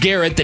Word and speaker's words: Garrett: Miles Garrett, that does rhyme with ferret, just like Jarrett.